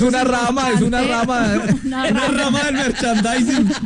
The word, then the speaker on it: merchandising: No, hay una mala pronunciación. Es merchandising, correcto, sí, ahí se.